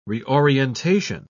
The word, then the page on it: reorientation: British English